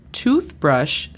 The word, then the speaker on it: toothbrush: La pronunciación fonética es P-I-L-O-U. – Pillow. – Muy bien. Después tenemos toothbrush. Toothbrush significa cepillo de dientes. La pronunciación fonética es T-U-T-H-B-R-A-S-H.